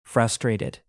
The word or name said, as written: frustrated